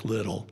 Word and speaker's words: Liddell: Eric Liddell, or I keep pronouncing his name wrong, Eric Little, because it's L-I-D-D-E-L-L. And I always want to say Liddell. It's Little. Eric Little was one of the fastest runners in the world.